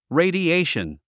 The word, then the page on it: radiation: rèidiéiʃən; レィディエイション